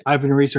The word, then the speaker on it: Ivan Reis: Um, I'm really looking for the Yvonne Hayes, uh, Yvonne Re- Ivan Reis, or however we say his name these days, uh, variant, and the 1 in 25 Daniel Sampere cardstock variant with Hal and the Jets is really cool too.